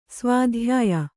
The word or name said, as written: ಸ್ವಾಧ್ಯಾಯ